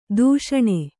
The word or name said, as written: ಧಿಷಣೆ